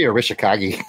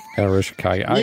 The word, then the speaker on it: Arashikage: Ash- Ashikagari. I always get that name wrong, but Ashikagari clan or I see Arashikage. Arashikage.